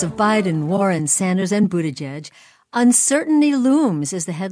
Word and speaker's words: Buttigieg: And if you don't yet know how to pronounce candidates' name, say Buttigieg, just go outside. – Buttigieg! Buttigieg!